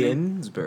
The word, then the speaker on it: Ginsburg: Ginsburg.